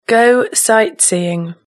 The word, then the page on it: go sightseeing: British English